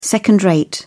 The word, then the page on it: second-rate: British English